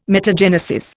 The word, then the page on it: metagenesis: Indian